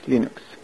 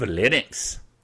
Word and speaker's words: Linux: Hello, this is Linus Torvalds, and I pronounce Linux. See? As Linux. – Linus Torvalds. He is the creator of Linux.